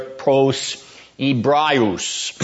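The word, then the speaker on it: pros Hebraeus (Πρὸς Ἑβραίους): the Greek there, pros Hebraeus, is how you would pronounce that, to the Hebrews.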